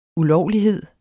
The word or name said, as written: ULOVLIGHEDERNES